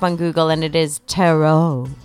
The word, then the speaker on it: tarot: And of course, famous tarot section. – Ah, yes, the tarot. – Tarot. – Rhymes with carrot.